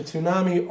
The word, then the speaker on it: tsunami: You don't pronounce the T in tsunami.